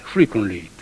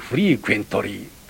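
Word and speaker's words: frequently: よくフリーケンシーとかフリークエンシーと言われますが、あれはフリークンシーでして、たびたびという、それから今度たびたびという副詞ができて、フリークンリーという言葉が、フリークンリー、アメリカ人が発音するのを聞くとフリークンリーと聞こえますね。あれ、学校なんかでよく英語の時間にフリークエントリーというふうに言われていますが、そんな発音じゃないです。フリークンリーで、たびたびということは周波数のように波がこうたびたび出てくるという意味から、たびたびという副詞がフリークンリーとなったわけです。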